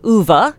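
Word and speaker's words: Ove: My emergency book is a book called A Man Called Uva by Frederick Backman. And yes, that is how you pronounce Uwe.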